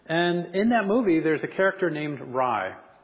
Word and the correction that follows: Rey: Rai, is that right? For all the little ones? No? Rey. Is it Rey? Oh, thank you.